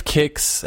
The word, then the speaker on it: Qix: Which I've heard of Kix. I think Nissan Games has done a couple reviews on the game. It's spelled Q-I-X.